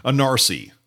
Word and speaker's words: ENARSI: I pronounce that as a NARSI.